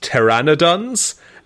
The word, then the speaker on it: pteranodons: At one point during the trip, Tanya became lost, and Carol found her in a cave being attacked by gigantic Paternodons. – This took a twist. What are pteranodons? – I think it's actually pronounced pteranodons.